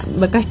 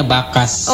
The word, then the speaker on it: Bakas: Oke deh, Bang, lagunya nih yang mau diputerin di pagi hari ini ada Bekas Jawa nih, requestan dari bukan bekas loh, itu dibacanya bakas.